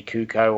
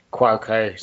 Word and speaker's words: Cuoco: And I think they'll do right by Harley Quinn and with Katie Kuko or however you say her name. Cuoco. Uh, Cuoco, sorry.